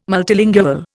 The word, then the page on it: multilingual: Indian